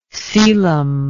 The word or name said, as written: caelum